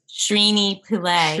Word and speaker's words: Srini Pillay: Srini Pillay. – Srini, yes, Pillay. Srini Pillay.